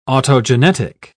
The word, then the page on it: autogenetic: British English